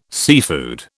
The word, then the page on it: seafood: British English